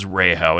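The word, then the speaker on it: Rehau: Yeah, so now, I guess, if you could tell me, who is Rahow? And now that's, I mean, if you look at it, it's R-E-H-A-U, but it's pronounced Rahow.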